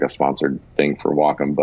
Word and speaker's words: Wacom: But yeah, it is Wacom. And I mispronounced it for years.